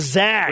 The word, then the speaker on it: Zags: Not the Zogs. What are you, what are you They're from, the Zags.